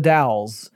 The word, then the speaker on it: Dalles: Dalles.